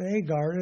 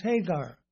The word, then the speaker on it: Hagar: But it's not Agar, it's Hagar. And in the Greek New Testament, it should be pronounced Hagar.